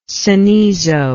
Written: cenizo

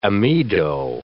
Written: amido

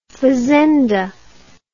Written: fazenda

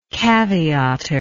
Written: caveator

